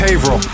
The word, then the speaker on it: Haverhill: Yeah, it's Haverhill. – Why can I never say that right? Haverhill, Haverhill.